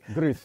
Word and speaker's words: gris: How you say gray in Spanish? – Everywhere, Gray Rizzy. – Okay. – Grizzy? – Gris. – Gris. Gris. Gris Rizzy, man. – Gris, all right.